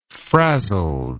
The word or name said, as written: frazzled